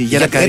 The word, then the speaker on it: γερακαρία: Γιατί τα τονίζεις έτσι, γερακαρία? – Έτσι τα λέμε στη Ζάκηθο. Είναι αυτό, ναι, που λέμε φωτία, κλωτσία. – Α, ναι. – Τονίζουμε στην παραλίγουσα πολλές λέξεις.